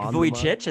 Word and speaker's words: Vujicic: But, so you know, here's Nick the best way to pronounce his name. It's kind of like the word voyage, Nick Vujicic.